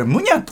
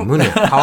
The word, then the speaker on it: MUNIYA: はい、えー、ムニャのアンドゥ・トロア。これ、ムニャって読むのね。 – MUNIYA でムニャ。